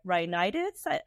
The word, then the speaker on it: rhinitis: But before we delve into that, why don't we just first start with defining what are allergies versus allergic rhinitis. Am I pronouncing that right? – Yes.